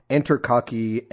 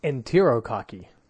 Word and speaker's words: Enterococci: coli, Enterococci, and Campylobacter. I may have pronounced some of those wrong. I am not a doctor. – It's Enterococci. – Oh, Enterococci. Okay, cool.